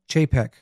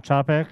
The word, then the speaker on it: Chapek: Hey, I got a pronunciation of his name is Chapek. – Oh, it's not Chopek? – It's Chapek. – Oh, okay. – The A is A.